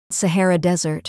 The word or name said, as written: Sahara Desert